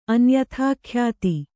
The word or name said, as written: ಅನ್ಯಥಾಖ್ಯಾತಿ